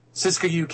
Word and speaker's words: Siskiyou: How do you say that? Siskiyou? Siskiyou County, California.